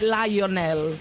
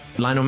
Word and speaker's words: Lionel: Tom Holland, que hizo el adelanto con el 10 de la selección, le dijo, no es Lionel. – Lionel Messi. – I have. – Yeah, where did you meet Lionel? – In Miami.